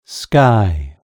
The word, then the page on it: sky: British English